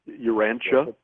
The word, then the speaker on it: Urantia: Urantia. – Urantia. – U-R-A-N-T-I-A, Urantia. – U-R-A-N-T-I-A. – U-R-A-N-T-I-A.